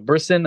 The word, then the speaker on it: Brisson: This is when Marvel finally started distributing the Predator run, written by Ed Brisson, I believe is how you say his last name.